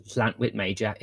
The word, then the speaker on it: Llantwit Major: From a famous goalkeeper, but also employing the histrionics of Llantwit Major itself.